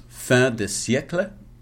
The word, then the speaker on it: fin de siècle: It is fin de siècle.